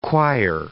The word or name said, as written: choir